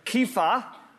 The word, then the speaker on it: Kepha: It's actually pronounced in Aramaic. Now we have an Aramaic term, Kepha.